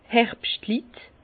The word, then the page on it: d' Herbschtlit: Bas-Rhin; Herrlisheim